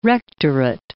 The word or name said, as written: rectorate